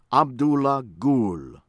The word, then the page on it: Abdullah Gul: American English